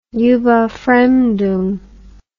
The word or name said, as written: Überfremdung